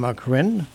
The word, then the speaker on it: Corrine: And welcome, Corrine.